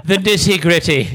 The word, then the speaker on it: nitty gritty: Except, of course, we pronounce the T's. – The nitty gritty.